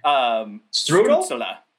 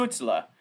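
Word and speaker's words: Stützle: I know who's listening, but yeah, it's Stutzla. So Stutzla. You have to put the la at the end. – Stutzla. – Or you could go really hard with the German and go, you know, Stutzla.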